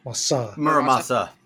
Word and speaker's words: Muramasa: Muramasa.